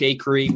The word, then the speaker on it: Acree: I'm on social at Luke Acree. My last name is spelled A-C-R-E-E. So think Acre with an extra E.